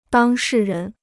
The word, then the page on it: 当事人: dāng shì rén